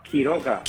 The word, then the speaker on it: Quiroga: Key, like the key for your car. Quiroga.